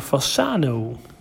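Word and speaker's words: Fasano: Ja, volgens mij is dat in het zuiden, in Fassano. – Dat, zeg ik dat goed? – Ja, dat klopt inderdaad.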